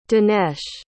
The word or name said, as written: danesh